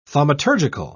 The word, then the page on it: thaumaturgical: British English